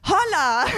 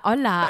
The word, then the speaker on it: hola: Und dann kamen wir in das Land Spanien und haben den Brief geöffnet und Emmy liest laut vor, Holla, willkommen in Spanien. Und ich so, Holla? Na, Holla. Und dann habe ich gesagt, wie wird denn Hallo auf Spanisch ausgesprochen. Weißt du es jetzt? – Na, hola. Aber das Ding ist,